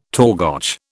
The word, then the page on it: torgoch: British English